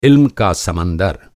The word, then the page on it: इल्म: عِلم